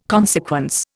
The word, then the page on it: consequence: Indian